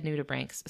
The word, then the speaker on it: nudibranchs: So anyway, I heard someone refer to them as nudibranchs, and I remembered that we had called them nudibranches, and I felt really self-conscious about it. So just to double-check myself, I messaged the Monterey Bay Aquarium and asked them how they pronounce it, and they said nudibranchs, so I'm going to take their word for it and apologize for mispronouncing nudibranch over and over again.